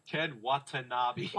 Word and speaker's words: Ken Watanabe: Ken Watanabe.